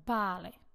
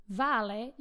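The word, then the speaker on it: vale: Napríklad bále. Vále, ja poviem ako Slovenka vále. Hej, nemám takú tú ich španielskú výslovnosť, preto som Slovenka. Môžem povedať bále.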